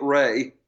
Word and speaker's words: Ralph: But he insists on pronouncing it Ray.